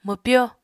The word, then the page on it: mwpyo: Apatani